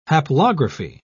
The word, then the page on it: haplography: British English